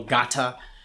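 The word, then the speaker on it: Ngatta: My name is Samuel Gatta. I have an N on there. Everybody says Nagata. The N is silent.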